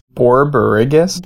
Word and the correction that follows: borborygmus: Borborygmus. B O R. – Miss. Okay. – B O R Y G M U S.